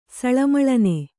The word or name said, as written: ಸಳಮಳನೆ